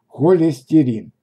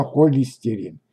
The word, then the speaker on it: холестерин: Холестерин. Неправильно холестерин. Как и с большинством неродных нашему языку слов, с ним возникают сложности. Холестерин – это особенно, особенное жирное вещество, содержащееся в, жи в желчи.